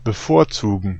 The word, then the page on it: prefer: GenAm; /pɹɪˈfɝ/; American English; [pɹɪˈfɝ]